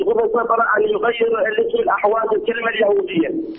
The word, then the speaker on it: الأحواز: أنت تريد الأحواز بالحاء أم بالهاء؟ – بالحاء. – بالحاء. – الأحواز الأحواز بالحاء. – بالهاء أم بالحاء؟ – بالحاء. – بالحاء. طيب، هو هكذا نحن ننطقها الأحواز، ربما أخطأوا وكتبوا الأهواز، مكتوب الأحواز.